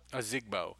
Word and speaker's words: Ozigbo: Isn't it Ozigbo? Ozigbo? – Ozigbo. So, yeah.